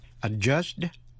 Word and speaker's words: adjudged: Quý vị có trả lời là The court adjudged that he was not guilty. Adjudged, đi thứ nhất, câm, không phát âm.